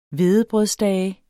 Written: hvedebrødsdage

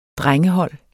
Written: drengehold

